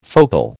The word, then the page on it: focal: American English